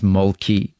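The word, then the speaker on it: Mölkky: Molky. I said Molky. Am I pronouncing it right? – We use Molky. Most of the people we play against use Molky, but the Finns have a very unusual pronunciation, which, please forgive me, I'll try it.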